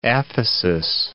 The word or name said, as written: aphesis